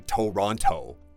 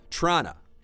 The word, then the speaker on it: Toronto: But people from there, they don't say Toronto. – 他们说 Toronto.